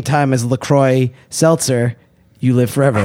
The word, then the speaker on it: LaCroix: So OIX would be pronounced wa in French. So it's therefore true. I go with LaCroix. I know it's LaCroix. – One thing we could agree on is the X is silent no matter what.